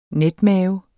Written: netmave

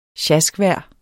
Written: sjaskvejr

